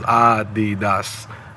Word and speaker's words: Adidas: and the big three-stripe gorilla in the room is three syllables, Adidas.